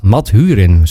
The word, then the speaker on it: Mathurin: Jetty Marhurin. Mathurin, zo moet ik hem volgens mij uitspreken.